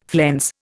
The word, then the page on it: flense: Indian